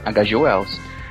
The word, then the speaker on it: H.G. Wells: H.G. Wells.